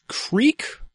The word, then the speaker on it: creek: Oh, also, just in case you're from like Central Kansas or Nebraska and have been confused, it is Creek. Creek is spelled the way that you spell Crick. – I've heard that people pronounce it Crick, but I've never actually heard somebody say that.